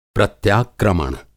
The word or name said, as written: प्रत्याक्रमण